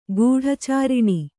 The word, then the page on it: ಗೂಢಚಾರಿಣಿ: guḍha cāriṇi